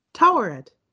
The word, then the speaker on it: Taweret: How do you say it again? The hippo. – Taweret. – I'm sorry, I forgot the T at the beginning.